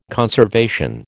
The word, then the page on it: conservation: American English